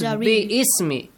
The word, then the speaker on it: Bismi: Bismi. It is Bismi. If you do not pronounce that Ba, you have to put a kasra at the bottom of alif and you say ism, right, Mahdi? – Ism means name.